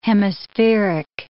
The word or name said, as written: hemispheric